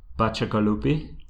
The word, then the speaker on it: Bacigalupi: I'm sorry, Paolo Bacigalupi.